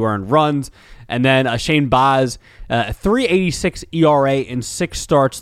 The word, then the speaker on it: Baz: Is it Baz or Baz? I cannot remember, but Baseball Reference is right in front of me, so I could always look there. The pronunciation is Baz. I was right.